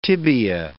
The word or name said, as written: tibia